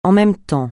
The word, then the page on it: en même temps: français